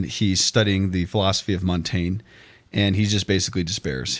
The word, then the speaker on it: Montaigne: Not like it's spelled like Devin Montaigne, who doesn't listen to the podcast.